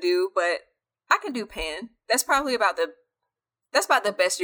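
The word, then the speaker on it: Paan: They are calling them, and I'm assuming you pronounce it as Pan, because it's P-A-A-N.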